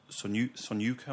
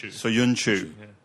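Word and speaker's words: Söyüncü: You know, like I even wonder, like if Evans and Sonyuka, am I pronouncing the... – Soyuncu.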